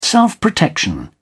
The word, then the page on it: self-protection: British English